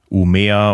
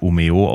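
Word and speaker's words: Umeå: Oder hier in Schweden, Umea, oder ich glaube, es wird Umeo ausgesprochen, keine Ahnung.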